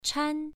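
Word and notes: chan: tone 1

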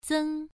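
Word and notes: zeng: tone 1

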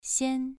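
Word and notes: xian: tone 2

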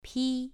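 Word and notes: pi: tone 2

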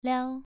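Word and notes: liao: tone 2

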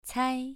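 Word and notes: cai: tone 2

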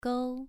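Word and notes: gou: tone 2

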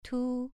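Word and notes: tu: tone 2

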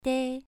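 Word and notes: dei: tone 2

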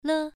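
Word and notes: le: tone 1

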